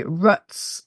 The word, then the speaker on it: Rutz: So I am Claire, which is spelled C-L-A-R-E, followed by Rutz, which is R-U-T-Z.